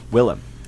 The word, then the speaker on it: Willem: Yes, William Dafoe. – Not William. Willem. – It's Willem. Is it Willem? Oh, I mean, I've been saying that wrong my entire life.